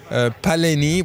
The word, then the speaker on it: Paleni: Paleni, we have to pronounce it the French way.